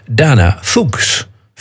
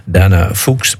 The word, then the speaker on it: Fuchs: En dan ben je heel sterk geneigd om het uit te spreken als Dana Fuchs. Fuchs. Fuchs. Ja, zo schreef je het. F-U-C-H-S. Maar als je dat dan opzoekt op in Wikipedia, dan staat er keurig netjes Dana Fuchs, pronounced as Fuchs. Fuchs. Dana Fuchs. Nou, ik hou het toch maar op Dana Fuchs.